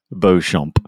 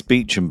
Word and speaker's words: Beauchamp: Beauchamp. Thinking I was being ever so correct. – Beauchamp Place, yes. Yeah. My favorite one was showing Americans, you know, High Wycombe, and seeing how they pronounce that one, you know. High Wycombe, yeah. – Wycombe. It's Beecham, by the way.